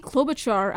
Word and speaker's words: Klobuchar: Amy Klobuchar, I'm sorry if I'm mispronouncing her name, but I mispronounce people's name on the show all the time.